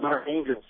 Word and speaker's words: Arkhangelsk: First of all, can you start off by telling us how to pronounce the name of the city and then give us some background information on these Arctic forums and your role in the event? – Sure. The meeting was called Arkhangelsk.